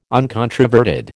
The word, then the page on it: uncontroverted: American English